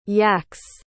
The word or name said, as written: yakez